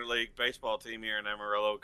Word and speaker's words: Amarillo: It's pronounced Amarillo, but that's just...